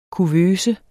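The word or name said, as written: Kuvøsen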